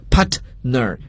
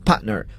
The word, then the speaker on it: partner: 伙伴合作者 partner 英语中呢，往往如果这个 T 爆破音放在词中时呢，我们要空出它的发音位置，不要给它去发音，所以这个词大家千万不要读作 partner，这样就是一种错误的发音，因为 T 放在词中失去爆破，空出其发音位置。 partner 伙伴有时也可以指合作伙伴，在中国就一般我们说这个同座位啊，就是用这个词 partner。